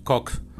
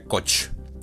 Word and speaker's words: Koch: Nikki Haley cuenta con, entre otros, un gran donante que es Charles Koch Se escribe con K y se K-O-C-H, o sea, en castellano se pronunciaría Koch. Creo que en inglés lo deben pronunciar Koch al fin de cuentas ese apellido no es tampoco original anglosajón, creo que viene de Alemania X.